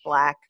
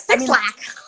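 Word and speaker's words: 6LACK: It's spelled six lakh. So if you've seen that and you're like, how do I pronounce it? It's just pronounced Black.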